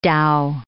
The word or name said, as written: dhow